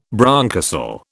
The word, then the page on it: bronchocele: British English